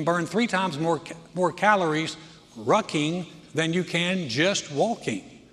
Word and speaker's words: rucking: That's with an R. Rucking. R-U-C-K-I-N-G. I had to spell it, as you thought it was another word that I was saying.